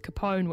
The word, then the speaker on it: Capone: We're going to leave with Capone, which is also from 4 Channel Tracks. – Yes. – Now that I know that's how you say that properly.